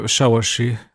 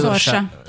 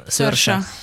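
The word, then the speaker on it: Saoirse: Saorsi Ronan, akit nyilvánvalóan nem így kell kiejteni, hanem... Szörse. Szörse.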